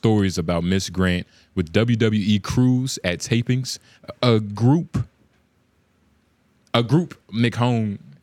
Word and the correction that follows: McMahon: is that how you say his name? McMahon.